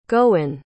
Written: goen

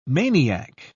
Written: maniac

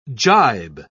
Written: gibe

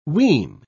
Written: ween

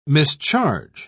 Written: mischarge

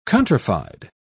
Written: countrified

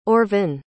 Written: orvyn

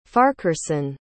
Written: farquharson

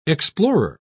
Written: explorer

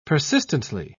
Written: persistently

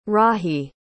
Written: rahi

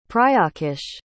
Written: prayaksh

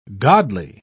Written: godly

